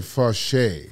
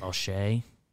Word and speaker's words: Faucher: They used Bard and Faucher, F-A-U-C-H-E-R. – I think they pronounce it Fauché. – Fauché.